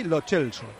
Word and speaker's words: Lo Celso: de tiempo de juego, que la pronunciación con la que se le conoce en Argentina es Giovanni Lo Celso. – Lo Celso, es que en Argentina suena Lo Celso...